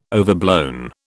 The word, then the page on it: overblown: British English